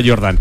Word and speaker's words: Jordan: Que se escribe Jordan, pero como Michael Jordan, ¿no? Úrsula Jordan.